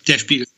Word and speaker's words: Der Spiegel: I saw in, now I hope I'm pronouncing this right, is it Der Spiegel? – Yeah, Der Spiegel.